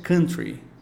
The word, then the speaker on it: country: Sobre música, a pronúncia correta de um dos estilos musicais é country. Mais uma vez, country. E algumas vezes aqui no Brasil a gente ouve as pessoas pronunciando country e está errado. A pronúncia correta, então, é country.